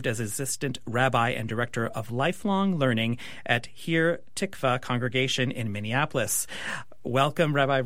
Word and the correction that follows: Shir Tikva: You minced there. I didn't say that correctly, did I? – It's Shir Tikva. That's okay. – Shir Tikva. Thank you for Hi.